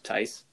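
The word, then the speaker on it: Theis: Daniel Theus, these Tice, Tice, Tice. really? yeah, that's an interesting pronunciation when it comes to the way that that's spelled.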